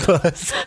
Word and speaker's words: Buz: Maybe it's Buzz. – Buzz. It is spelled B-U-Z, so.